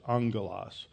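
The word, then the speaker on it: angelos (ἄγγελος): Now, you see that I spell that A-G-G. In the Greek, when you have a double G, it's pronounced like an N-G in English. It's angelos. So this is an angelos, an angel of Satan.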